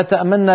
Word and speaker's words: تأمننا: مثلا لا تأمننا لا تأمننا لا تأمننا بالروم هكذا بالروم وهو تبعيض الحركة هكذا ما لك لا تأمننا على يوسف تنطق نونين والنون الأولى تسرع عندها قليلا مع خفض في الصوت